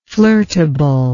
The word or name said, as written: flirtability